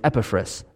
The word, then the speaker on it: Epaphras: Uh, if you're interested in the uh, correct pronunciation, it's Epaphras, but I'm not doing that. So um, it's Epaphras, because that's how I've been saying my whole life, and I'm going to keep going with it.